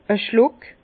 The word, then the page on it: e Schluck: Bas-Rhin; Herrlisheim